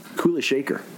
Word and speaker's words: Kula Shaker: The Kula Shakar, is that how you say it? That song? – Kula Shaker.